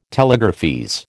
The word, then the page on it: telegraphese: American English